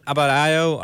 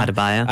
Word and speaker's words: Adebayo: You you really think of Goran Dragic and Abad Abadayo? I can't even pronounce his name right now. Adebayo.